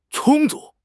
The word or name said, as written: 充足